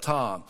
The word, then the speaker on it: Ptah: Memphis was believed to be under the protection of the god Ptah. And maybe it's more like a silent P on the front of that Ptah.